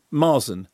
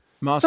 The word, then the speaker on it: Marsan: Eddie, what's the absolutely definitively correct pronunciation? – Marsan. – Thank you. – It's a soft S, yeah. – Okay, so Marsan. – It's French, yeah.